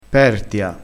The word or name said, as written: pertia